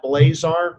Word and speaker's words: Blazar: You can go to Amazon and just do a search by my name as the author, Brad Blazar. It's spelled B-L-A-Z like zebra, A-R.